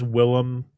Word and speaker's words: Willem: Technically, it is Willem. That's how people are supposed to pronounce it, but his actual name is William, but he changed it to be different.